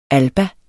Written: alba

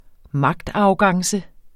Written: magtarrogance

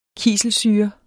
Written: kiselsyre

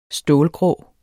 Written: stålgrå

